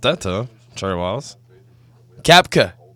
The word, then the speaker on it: Koepka: You cannot get his name right. – Jason Martin's about to join us right now. – God. Brooks Koepka.